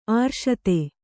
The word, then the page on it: ಅರಸಿತಿ: arasiti